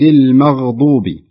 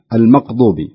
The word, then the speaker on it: المغضوب: هناك خطأ أسمعهم بعض الناس وقد يكون أيضا بسبب اللهجات، فصراط الذين أنعمت عليهم غير المغضوب، فتجد يقول غير المقضوبي بالمقضوبي الغين تنطق قاف تنطق قاف والخطأ هو سببه أنه يحرك الغين من مخرج القام من مخرج الحلق إلى مخرج اللسان درجة